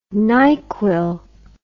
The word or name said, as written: nyqull